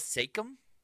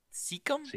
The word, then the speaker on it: Siakam: Pascal Siakam? – Siakam.